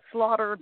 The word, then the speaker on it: Slaughter: I love to say, Deb, my last name is Slaughter, but that's S and Laughter. So that's how you spell it, is S and Laughter.